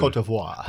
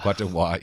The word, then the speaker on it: Côte d'Ivoire: Côte d'Ivoire. – Côte d'Ivoire, yeah, you got to say it through your nose.